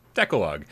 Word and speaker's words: Decalogue: Game Cat with $5 tip says it's pronounced Decalogue, and it means the Ten Commandments.